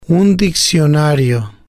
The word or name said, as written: un diccionario